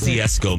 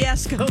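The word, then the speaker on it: Kosciusko: I might not be saying that right. I apologize. – Kosciuszko, Mississippi. – Kosciuszko. Thank you.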